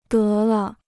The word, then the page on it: 得了: dé le